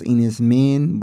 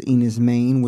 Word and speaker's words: Enys Men: I thought it's pronounced Enys Men, but it's Enys Men, which is Cornish for Stone Island.